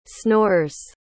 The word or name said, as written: snores